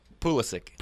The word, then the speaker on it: Pulisic: Redemption for Polski. Did I say that right? Pulisic? – Pulisic. – Pulisic, okay. Thank you.